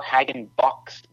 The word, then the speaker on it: Hagenbuch: Well, we're sure, of course, that the patriarch, as we call him, Andreas Hagenbuch, who came from Germany in 1737, which is my fifth great-grandfather, he would have pronounced Hagenbuch, very German.